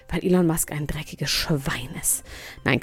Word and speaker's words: Elon Musk: Elon Musk, ich habe jetzt gerade den Namen das erste Mal richtig ausgesprochen. Ich werde so viel gehatet, mein Gott, weil er nicht Elon Musk, sondern Elon Musk heißt. Jetzt sage ich es immer richtig.